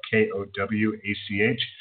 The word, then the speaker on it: Kowach: My last name is spelled K O W A C H um, has uh, the Diligent Watchman podcast on there.